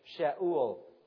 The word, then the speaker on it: Sheol: The actual correct pronunciation is Sheol.